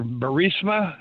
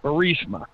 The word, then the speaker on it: Burisma: Mr. Burisma. Did I say that right? – Yeah. – You know, the guy that got the Ukrainian prosecutor fired that was investigating his son, Hunter. – Yes. – Burisma.